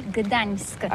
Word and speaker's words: Gdańsk: Alors d'abord, comment prononce-t-on bien? Ça s'écrit G-D-A-N-S-K. On dit Gdansk ou Gdansk, on prononce le G ou pas? – Oui, c'est pas très facile pour des étrangers, mais remarque bien que la langue polonaise, c'est la langue où on prononce chaque lettre qu'on écrit. C'est pourquoi on dit Gdańsk.